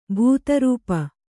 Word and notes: ಭೂತರೂಪ: bhūta rū`pa

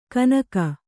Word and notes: ಕನಕ: kanaka

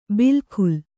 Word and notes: ಬಿಲ್‍ಕುಲ್: bil kul

